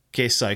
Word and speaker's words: Keirsau: It's like K-E-I-R-S-A-U. – Kierso.